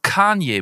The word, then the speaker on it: Kanye: Warum sagst du zu Kanye zum Beispiel immer Kanye? Der wird doch Kanye betont.